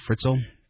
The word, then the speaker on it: Fritzl: Well, it turns out that Fritzl claims, am I saying his name properly, Fritzl? – Sure.